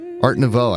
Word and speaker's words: Art Nouveau: Art Nouveau, I think, is how you pronounce it.